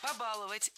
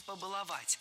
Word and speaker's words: побаловать: Побаловать – это тоже сейчас правильный вариант, наряду с вариантом «побаловать». Раньше требовалось в глаголах с корнем «бал» ставить ударение только за корнем. Но сейчас возможны варианты «баловать» и «баловать», «побаловать» и «побаловать».